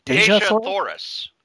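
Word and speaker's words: Dejah Thoris: And tell me if I'm pronouncing this right. Deja Thoris? – Deja Thoris. – Yes.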